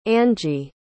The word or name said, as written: angie